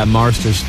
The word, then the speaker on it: Marsters: We're going to talk to James Marsters. That's how you say it, right? – Yeah, Marsters. Thank you.